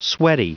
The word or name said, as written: sweaty